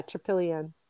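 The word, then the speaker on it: Trypillian: But you also mentioned Tripillion. I'm going to say that wrong. Tripillion. – Yeah. Tripillion.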